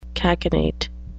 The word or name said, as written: cachinnate